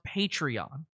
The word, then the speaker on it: Patreon: I'm just telling you that there will be one missed show next Wednesday, one missed patron show, and I apologize. – He is accidentally calling Patreon patron. So that's official. He doesn't know it's pronounced Patreon. He thinks that's how you spell the word patron.